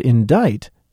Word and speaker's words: indict: The word indict means to charge someone with a crime, and even though it's spelled with a C, the C isn't pronounced. And I learned that racketeering has absolutely nothing to do with tennis.